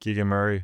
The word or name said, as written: Keegan Murray